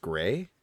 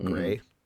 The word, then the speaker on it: Gris: I got that game called, I think it's pronounced Gray. It's spelled G-R-I-S, but people call it Gray.